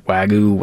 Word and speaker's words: Wagyu: Wagyu.